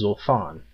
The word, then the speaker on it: Zul'Fan: which is pronounced Zul'Fan. Zul is, you know, the generic troll prefix